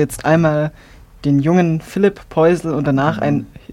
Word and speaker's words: Poisel: Ja, von Philippe Poisel, das ist die Marke, sein Künstlername Poisel, französisch ausgesprochen.